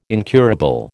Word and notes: incurable: American English

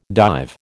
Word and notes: dive: American English

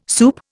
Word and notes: soup: Indian

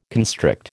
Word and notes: constrict: American English

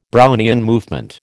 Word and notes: Brownian movement: American English

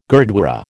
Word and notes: gurdwara: American English

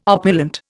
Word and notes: opulent: Indian

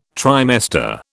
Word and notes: trimester: British English

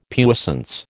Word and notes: puissance: American English

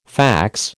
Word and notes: fax: American English

